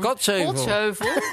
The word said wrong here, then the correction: Kaatsheuvel: Kaatsheuvel? – Kaatsheuvel, ja. – Dit is, er is zo'n lettergreep, nadruk, hoe noem je dat ook alweer? – Fout, gewoon. Het is fout, zoals jij het zegt.